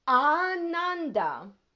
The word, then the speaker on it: Ananda: Ananda. Long A, the first A being long.